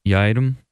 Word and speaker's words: Yiadom: Yiadom.